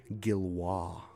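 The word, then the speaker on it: Gillioz: Gilwaugh. Yeah, you got to be a seasoned DJ to remember that's pronounced Gilwaugh.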